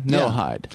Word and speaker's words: Noahide: I called in to discuss what they call the no-eyed laws. Now, that's spelled... – The no-what? – Okay. It's spelled N-O-A-H-I-D-E. It has Noah in it. – Okay. No-hide.